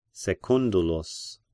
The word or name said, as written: Secundulus